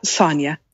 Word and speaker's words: Sonja: It's Sonja.